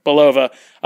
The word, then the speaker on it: Belova: Belova.